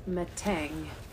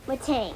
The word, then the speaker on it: Metang: Let's check the pronunciation guide. It says Metang. – Metang.